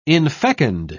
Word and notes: infecund: British English

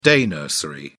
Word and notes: day nursery: British English

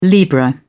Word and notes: libra: British English

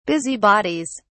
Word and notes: busybodies: British English

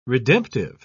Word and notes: redemptive: British English

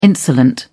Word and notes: insolent: British English

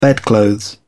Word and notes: bedclothes: British English

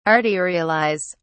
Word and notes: arterialise: British English

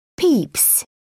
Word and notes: peeps: British English

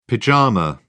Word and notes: pyjama: British English

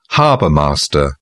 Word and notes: harbour master: British English

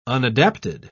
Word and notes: unadapted: British English

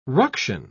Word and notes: ruction: British English

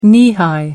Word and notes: knee-high: American English

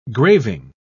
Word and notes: graving: British English

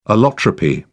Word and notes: allotropy: British English

